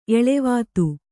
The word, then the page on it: ಎಳೆವಾತು: eḷevātu